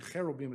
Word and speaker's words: cherubim: Actually, cherubim is how you say it, but anyway.